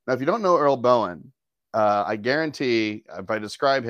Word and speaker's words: Boen: How would you say B O E N Bowen? – Yeah.